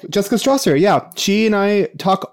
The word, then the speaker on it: Stawser: It's A Million Reasons Why, and it's by Jessica Strasser. S-T-A-W-S-E-R. She has... – Wait, say her name again? – Jessica Strasser. S-T-A-W-S-E-R.